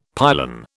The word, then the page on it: pylon: British English